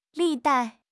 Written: 历代

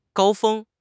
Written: 高峰

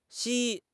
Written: Xi1

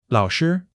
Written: lao3 shi1

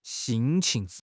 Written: 辛勤

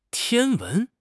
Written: 天文

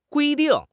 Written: gui1 ding4

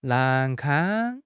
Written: 难堪

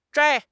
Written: zhai1

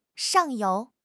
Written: 上游

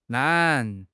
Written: lan3

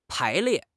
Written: pai2 lie4

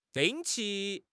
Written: 定期

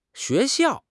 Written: xue2 xiao4

